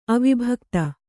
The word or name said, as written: ಅವಿಭಕ್ತ